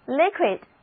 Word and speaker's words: liquid: liquid 既是形容词又是名词，两个字母 I 的读音都是短的 E。